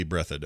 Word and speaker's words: Breathed: His name is Breaded. I've watched the documentary. They say it over and over. Breaded, breaded, breaded, breaded. – Well, if it's breaded, that's spelled B-R-E-A-T-H-E-D, yes.